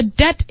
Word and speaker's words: debt: Yeah, if a debt is cleared, then the debt doesn't exist anymore. Then debt is just disappeared. – 就是免除债务。大家注意这个 debt, 这个单词 ,debt, 那个 b 呢是不发音的。